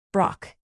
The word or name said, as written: brock